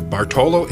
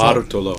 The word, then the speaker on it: Bartolo: this is the Bartolo in the Barber of Seville. Do I pronounce Bartolo.